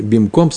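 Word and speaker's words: бимком (במקום): Э, только за это произносит бимаком. Вообще по закону нужно говорить бимком сакана.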